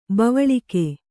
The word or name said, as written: ಬವಳಿಕೆ